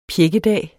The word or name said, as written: pjækkedag